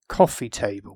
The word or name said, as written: cofee table